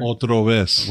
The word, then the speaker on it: Otra Vez: Yeah, we got Carlo Cortez with Otra Vez. Did I say that right? – Otra Vez.